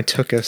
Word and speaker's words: tuchus: My tuchus. – Say that again. – Tuchus. – You're saying it wrong. – How do I say it? – Tuchus.